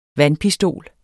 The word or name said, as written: vandpistol